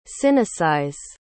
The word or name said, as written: sinicize